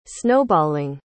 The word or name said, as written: snowballing